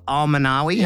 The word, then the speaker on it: Alminawi: I'm always excited, but I am super excited today to be joined by Swiftor, or lesser known as Joseph Al-Manawi. How'd I do? – Good pronunciation there.